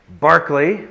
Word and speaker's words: Berkeley: And it is pronounced Berkeley, like Sir Charles, not Berkeley, like the college, which mispronounces his name, which was named after him.